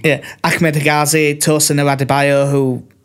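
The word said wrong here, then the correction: Adarabioyo: No, I said that wrong. Adarabayo, not Adebayo.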